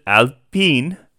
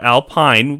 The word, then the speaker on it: Alpine: Looking ahead to the other team that's got a new name, and this is how you pronounce it, Alpine. It's not Alpine, which is what it looks like. – It's French.